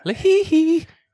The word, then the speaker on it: LaHaye: A-Y-E. – LaHaye. – I'm saying LaHaye. Is that or LaHaye? – He. LaHaye. – He. – Boy, we butcher everybody's names. – I'm sticking with LaHaye.